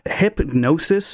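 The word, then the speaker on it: Hipgnosis: So it's a hypnosis. There's a G in there. It's really difficult to pronounce.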